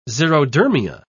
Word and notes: xerodermia: British English

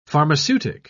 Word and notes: pharmaceutic: British English

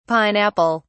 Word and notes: pine-apple: British English

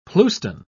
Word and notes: pleuston: British English

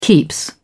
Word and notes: keeps: British English

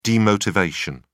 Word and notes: demotivation: British English

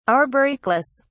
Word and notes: arboricolous: British English

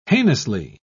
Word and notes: heinously: British English

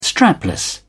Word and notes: strapless: British English

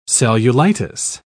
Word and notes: cellulitis: British English

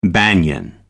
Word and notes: banyan: American English

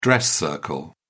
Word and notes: dress circle: British English

